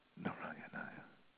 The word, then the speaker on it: Narayanaya: The Om Namo Narayana. See, Narayanaya. There's a particular pronunciation right now. I'm blocking on it. Sorry. Narayanaya. Narayanaya.